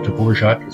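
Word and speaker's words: Dvořák: Because that's where Anton Dvorak, Dvorak, they call him out there in Iowa now, but Dvorak is closer to the real pronunciation.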